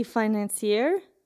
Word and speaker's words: financier: One example is she's like, the report says the likely financier is a close Putin ally with ties to Russian intelligence. Ally, sorry. Listen, okay? I'm sick from Rachel, okay? So likely financer. How do you say that? – Financier.